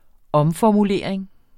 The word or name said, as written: omformulering